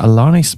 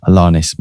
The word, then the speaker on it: Alanis: And before that, Alanis Morissette, That I Would Be Good. I've always presumed her name to have a silent S, like it's Alani Morissette, but I think it is actually Alanis Morissette.